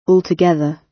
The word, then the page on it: altogether: British English